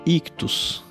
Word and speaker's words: ICHTHUS: vai em catarse.me barra ictus, catarse, um S só, ponto, me barra ictus. Ictus se escreve I-C-H-T-H-U-S.